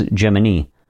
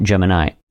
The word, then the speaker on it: Gemini: The official pronunciation, as stated by NASA in 1965, was Gemini, not Gemini.